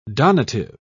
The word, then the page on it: donative: British English